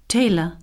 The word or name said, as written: tailor